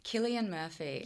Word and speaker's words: Cillian Murphy: Cillian Murphy.